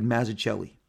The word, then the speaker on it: Mazzucchelli: Mazzucchelli? Okay, David Mazzucchelli.